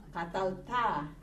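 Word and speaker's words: katalta (קָטַלְתָּ): Katalta. – Okay, very good. The accent is on the A, the last syllable, katalta.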